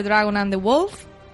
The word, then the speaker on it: Dragon and the Wolf: Bueno, pues lo dicho, vamos a comentar el episodio 7 de la séptima temporada, titulado en título original de Dragon and the Wolf. Qué bien lo he pronunciado. Estas chicas que saben mejor inglés que yo, a ver que Muy bien pronunciado.